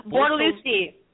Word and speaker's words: Bortolussi: Bortolussi. Yes, I'm here. – How do you say it? How do you say it? – Bortolussi.